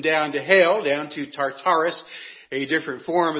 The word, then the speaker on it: Tartarus: This is T-A-R. U.S., we usually pronounce this as Tartarus.